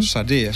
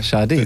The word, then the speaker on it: Sade: Sade. – Sade, siehst du, ich hätte es wieder falsch gesagt.